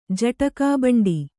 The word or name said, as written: ಜಟಕಾಬಂಡಿ